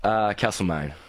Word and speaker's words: Castlemaine: Castlemaine.